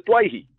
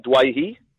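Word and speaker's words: Doueihi: Well, this week, going into game one of the World Cup, he has set the record straight and said, I'd like to be known as Dwayhi, which is the correct Lebanese pronunciation. So, we respect Adam's wishes. It is Adam Dwayhi from this day forth, Sats.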